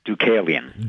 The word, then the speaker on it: Deucalion: Deucalion. – Deucalion, okay.